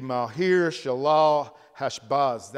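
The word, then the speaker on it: Maher-shalal-hash-baz: The actual pronunciation of that name would be Mahir Shalal Hashbaz.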